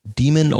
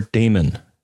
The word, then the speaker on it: daemon: It's probably a Unix daemon or daemon. And as far as I can tell, both pronunciations are correct, depending on where you went to school or how long ago you started using Unix. But I found both pronunciations being used.